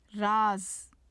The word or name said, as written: raz